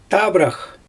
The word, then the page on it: tabrax: Xyzyl